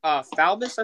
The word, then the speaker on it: Faubus: Governor Faubus, I think is how you pronounce his name.